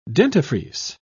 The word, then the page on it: dentifrice: British English